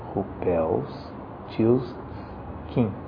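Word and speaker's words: Rumpelstiltskin: A pronúncia aqui no Brasil, que vocês verão, é Rupels-Tilskin. Rupels-Tilskin.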